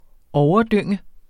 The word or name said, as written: overdynge